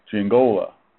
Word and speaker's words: Giangiola: It's the third syllables where the you put the what do you call it? The emphasis on. So the third syllable is go. So Giangiola.